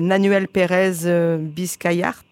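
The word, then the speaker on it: Nahuel Pérez Biscayart: C'est Nanuel Pérez Biscayart. – Très bien. – C'est vrai. – Tu l'as très bien dit.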